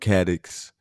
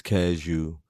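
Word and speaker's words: Cadieux: In Detroit, there is a street called Caddox. But if you're from, it's spelled Caddox. But if you're from Detroit, it's Cashew.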